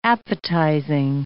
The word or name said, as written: appetizing